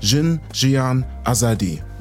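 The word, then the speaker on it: Jin, Jiyan, Azadî: Sie rufen rufen nieder mit der Diktatur und rufen Jin Jian Azadi.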